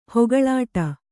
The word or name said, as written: ಹೊಗಳಾಟ